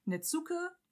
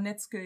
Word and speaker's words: Netsuke: Aber äh, das kommt tatsächlich ganz drauf an, in welchem Bereich das ausgesprochen wird. Weil im Bereich um Osaka herum wird äh, zu mhm. so ausgesprochen und in der Gegend um Tokio herum wird, äh, wird das U sozusagen verschluckt. Ah. Deswegen Netsuke geht, aber mhm. Netzke eben auch.